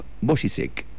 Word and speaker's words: Bořízek: Efectivamente, ha salido del tintero Jan Vaclav Bořízek, que se escribe Bořízek con K al final y con B corta.